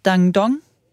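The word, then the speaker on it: Dandong: Jamen, jeg sidder og kigger på, jeg er inde på CNN, og jeg kigger på en bro mellem, og du kan udtale bynavnene, altså 10.000% mere korrekt, Dangdong. – Det lyder rigtigt, i Kina, og så Nordkorea.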